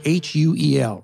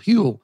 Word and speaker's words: Huel: Okay, I want to talk about Huel, uh, H U E L, rhymes with fuel. Huel.